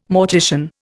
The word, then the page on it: mortician: Indian